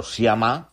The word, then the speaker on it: Sciamma: A ver, con diferencia es la peli de la semana para los cinéfilos, la última de Céline Sciamma, o Sciamma, si lo pronunciamos en francés, aunque el apellido es italiano, la de Water Lilies, Tomboy, Girlhood, y Retrato de una mujer en llamas.